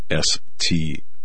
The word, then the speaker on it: Strzok: His last name is Strzok. I pronounced it Sturzok, but apparently the Z is silent.